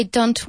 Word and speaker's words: don't: Pronounce the T in don't a bit more strongly. – Why don't we wait until tomorrow? – Wait. – Why don't we wait until tomorrow? – No, more don't.